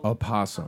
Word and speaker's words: opossum: It's pronounced with a U-H, the O. – Opossum.